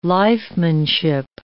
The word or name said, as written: lifemanship